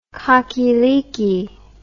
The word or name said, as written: cockyleekie